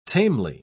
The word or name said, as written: tamely